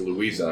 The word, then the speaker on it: Lueza: Luis de Vega? – Luisa de Vega. – Luisa de Vega. – And Carter Bay. – I got Carter Bay. – Luisa. – How do you spell it? L-U-E-Z-A.